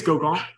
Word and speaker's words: Gauguin: So I think, like, if we're gonna say it, like, might as well say like Gauguin. So it's like the French pronunciation. – Gauguin. – I think it's Gauguin.